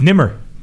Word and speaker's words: Nemr: Nobody gets it because it's spelled N-E-M-R.